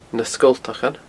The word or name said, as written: Na Sgoltachan